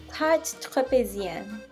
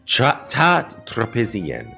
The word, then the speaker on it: Tarte Tropézienne: How do I say it correctly? – Tarte Tropézienne. – Tarte Tropézienne.